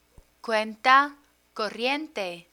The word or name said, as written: cuenta corriente